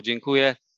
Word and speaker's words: Dziękuję: Dziękuję.